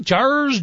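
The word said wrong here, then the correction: Jair: Jurgens? Am I pronouncing it correct? – Yair Jurgens. Yair Jurgens.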